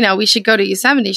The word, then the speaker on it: Yosemite: We're not even from this planet and we know it's Yosemite. – Yeah. – Yosemite.